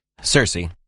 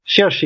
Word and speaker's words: Circe: Circe. – Circe, yeah, but you have to pronounce it with a K in Greek, and then it comes to Kirche, and in German it's still Kirche, so that's more closer to that word.